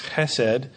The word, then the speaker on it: chesed: In the Old Testament, we often read of a particular term that in Hebrew is pronounced chesed, which is sometimes hard to translate into English, but it carries the idea of love, compassion, mercy, faithfulness.